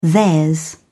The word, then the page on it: theirs: British English